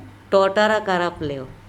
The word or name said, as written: ToTarakarapleo